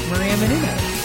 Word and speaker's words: Maria Menounos: Maria Menounos.